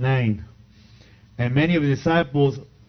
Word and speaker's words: Nain: Did I pronounce it correct, Pastor? Nain? The widow of Nain? Thank you.